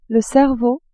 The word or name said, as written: cerveau